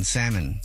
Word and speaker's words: salmon: Gosh, somebody else texted in at 41061 and said, I broke up with somebody because they pronounced the L in salmon.